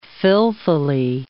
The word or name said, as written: filthily